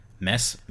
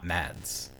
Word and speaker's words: Mads: Um, and another round won Best International Film, which has which I recently learned Mess Mikkelsen is how you pronounce it, not Mads. I always thought it was Mads.